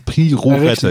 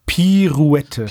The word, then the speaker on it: Pirouette: Aber wir haben ja im Vorgespräch festgestellt, dass ich Piorette nicht richtig aussprechen kann. – Pirouette, ja, genau. Pirouette.